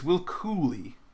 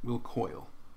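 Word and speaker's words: Cuylle: It's Will Cooley, not Will Coyle. It's one of those names where it's spelled in a way where you think maybe it's Coyle, but it's Cooley.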